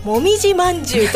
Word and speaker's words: MMJ: ちょっと例を挙げてみますと、広島県の MMJ と書いてもみじまんじゅうと読むグループ , 笑えますね、これね。